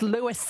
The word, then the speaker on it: Louis: It's not Lewis, it's Louis. But then this. – Hello. Dolly, this is Lewis. – Quite clearly the man himself is saying it's Lewis. – It's Lewis. Dolly. Lots of emphasis on the S.